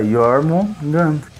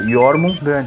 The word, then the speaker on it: Jormungand: Ué, Jormungand. – Então, você é fácil. Eu não sabia que era assim que pronunciava. – Vai, vamos aqui dentro do podcast ter uma aula de pronúncia. Fala Jormungand. – Jormungand.